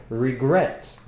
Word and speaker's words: regret: Regret. – Exactly. Regret has two syllables. Regret. The stress is on gret, the last one.